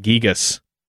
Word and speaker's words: Gigas: Pronunciations vary. You can say Gigas, Gigas, Gigas, Gigas. But we're going to go with the Greek origin, which uses the letter gamma for hard G's, pronouncing it Gigas.